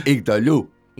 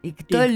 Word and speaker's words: Igtoluh: ¿lo digo bien? – No. – Decilo entonces bien. – Iktolu. – Iktolu. – Iktolu. Iktolu, como si llevara tilde en la U, creo que gramatismo debería llevarlo, ¿no?